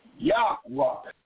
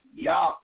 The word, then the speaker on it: Yahweh: And how you pronounce it, Nisi? Yahweh. That's how you pronounce his name, Yahweh.